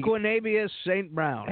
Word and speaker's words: Equanimeous St. Brown: To y'all, Equanimeous St. Brown from Notre Dame. – Okay. – I think he's a hell of a talent. And Equanimeous St. Brown.